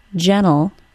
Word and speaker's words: gentle: Let's compare the words gentle, G-E-N-T-L-E, and gently, G-E-N-T-L-Y. The final syllable of the word gentle is just an L sound, ol. Yes, there is an E in the spelling, but it is not pronounced in any way. Gentle, gentle. In the word gentle, because the T is between an N sound and a syllabic L, the T sound can be omitted.